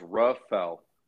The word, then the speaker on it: Rafael: It's just two syllables. Rafael.